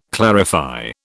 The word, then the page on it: clarify: British English